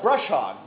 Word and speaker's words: brush hog: Not bush hog, brush hog, brush hog, brush hog. I didn't even say it right.